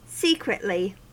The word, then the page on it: secretly: American English